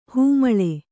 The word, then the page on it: ಹೆಮ್ಮೆಳೆ: kannada